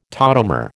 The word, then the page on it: tautomer: American English